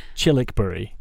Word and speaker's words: Childwickbury: So it's pronounced Chilwickbury, mm-hmm. but it's spelt Childwickbury. So Childwickbury, but it's Chilwickbury.